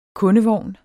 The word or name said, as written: kundevogn